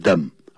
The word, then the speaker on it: them: Keep them, 那个 them 要念成 them, 念成弱音的音 them, 啊 and have them repaired.